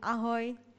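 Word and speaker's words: ahoj: Am I pronouncing it correctly? – Yes, it's ahoy.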